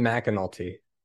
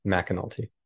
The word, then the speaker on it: McAnulty: It's Bryan McAnulty. Okay. So it's spelled M-C-A, but it's pronounced Mac, McAnulty.